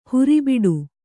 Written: ಹೊರೆಬಿಡು

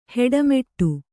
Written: ಹಿಟ್ಟುಮಟ್ಟಿ

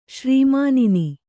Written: ಶ್ರೀಮಾನಿನಿ

